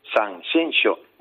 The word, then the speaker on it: Sanxenxo: Tengo un amigo, precisamente, Mairena del Aljarafe, policía, que para decir Sanxenxo me empezaba diciendo Sanxenxo, Sanxenxo. Y tienes que, bueno, corregirle. No, Eduardo, que no, que es Sanxenxo.